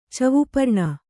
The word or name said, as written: ಚವುಪರ್ಣ